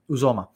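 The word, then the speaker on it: Uzomah: A lot of people keep talking about CJ Uzoma, or I keep forgetting how to pronounce that guy's name. CJ, who's your mama? – Yeah. – So Uzoma.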